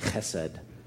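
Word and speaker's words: chesed (חסד): You need a little crud to say it properly, chesed.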